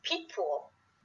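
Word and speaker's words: Picpoul: It's pronounced Peekpool.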